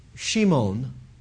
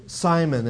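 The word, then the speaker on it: Shimon (שמעון): Uh, he doesn't use his he doesn't use his Hebrew name Shimon or Simon as we often mispronounce it. Of course, Greeks had that same problem, you know. What's your name? Shimon. I think he said Simon, didn't he? Yeah. Okay. Well, because the Greeks just couldn't pronounce that SH sound.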